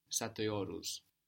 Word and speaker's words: Satıyoruz: Satıyoruz. – Good. But with a dotless I, no? Satıyoruz.